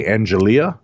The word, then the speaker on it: Angelia: A-N-G-E-L-I-A, Angelia? – Angelia Lynn Johnston.